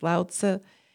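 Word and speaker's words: Laozi: And like Lao Tzu, Zhuangzi, it's actually pronounced Lao Tzu and Zhuangzi. They added that little Z-I.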